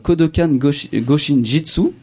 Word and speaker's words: Kodokan Goshin Jitsu: et plus particulièrement de Kodokan Goshin Jitsu, je le prononce bien.